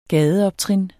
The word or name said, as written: gadeoptrin